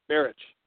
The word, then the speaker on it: Marich: Marich.